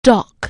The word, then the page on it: dock: anglais